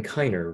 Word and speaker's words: Kiner: Yeah, it's Kevin Kiner, right? Is the way you pronounce his last name.